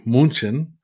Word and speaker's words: München: or as they say in Germany, München.